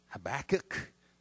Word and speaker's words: Habakkuk: I'm going to take us back to Habakkuk, all right, or however you say it. You may pronounce it Habakkuk. That's fine as well, all right.